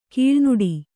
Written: ಕೀಳುನುಡಿ

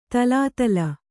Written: ತಲಾತಲ

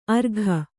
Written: ಅರ್ಘ

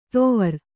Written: ತೊವರ್

